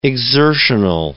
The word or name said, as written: exertional